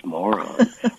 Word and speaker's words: Moran: While it's spelled M-O-R-A-N, it's pronounced moron.